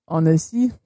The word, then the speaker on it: Annecy: Annecy.